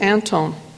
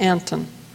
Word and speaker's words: Anthon: The family pronounces it Antone, which I believe is close to the Scandinavian pronunciation. Although Scandinavians tell me it's more often pronounced Anton.